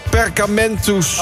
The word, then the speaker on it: Perkamentus: Het kantoor van Perkamentus.